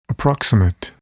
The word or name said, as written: approximate